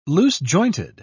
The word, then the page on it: loose-jointed: British English